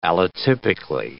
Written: allotypically

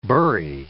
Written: burry

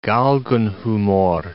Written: Galgenhumor

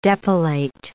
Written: depilate

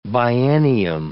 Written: biennium

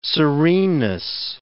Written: sereneness

